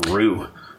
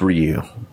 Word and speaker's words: Ryu: Like, is it Ryu, or Ru? – It's Ryu. – It's Ryu? – Yeah. – It's Ryu.